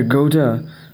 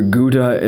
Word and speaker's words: Goethe: The German writer Goethe, or Goethe, if it depends on how, if you're pronouncing it English or German accent, it's G-O-E-T-H-E, Johann Wolfgang Goethe.